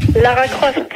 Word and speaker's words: Lara Croft: Ouais, ben, on va demander à Laura Graff. – Lara Croft. – Bon, la Croft. Ouais, ben, je sais pas qui c'est qui m'a écrit ce nom-là, mais je prononce comme c'est marqué.